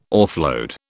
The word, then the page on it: offload: British English